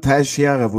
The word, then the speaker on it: Teixeira: Er möchte ausgesprochen werden, Teixeira, wurde mir Teich, gesagt.